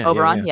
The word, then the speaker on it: Oberon: Is it pronounced Oberon? How's it pronounced? Oberon? – Oberon, yeah.